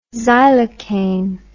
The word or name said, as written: xylocaine